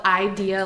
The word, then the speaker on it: Eyedea: It's spelled Idea, like E-Y-E-D-E-A, and he was around for, like, a very short time, an underground rapper like that.